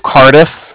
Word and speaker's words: Cardiff: From the Bank of Cardiff, and that's spelled C-A-R-D-I-F-F, as in Frank Frank. So it's like car and then different.